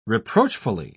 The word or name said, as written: reproachfully